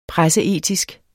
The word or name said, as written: presseetisk